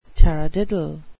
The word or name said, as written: taradiddle